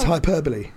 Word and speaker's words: hyperbole: What's the other one that everyone always gets wrong? A hyperbole. And it's hyperbole.